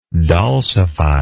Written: dulcification

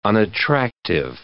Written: unattractive

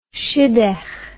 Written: shidduch

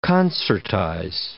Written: concertize

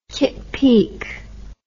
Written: kitt peak

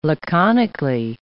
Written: laconically

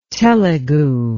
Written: telegu

